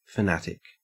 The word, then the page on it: fanatic: Australian English